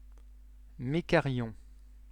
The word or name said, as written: mekaryon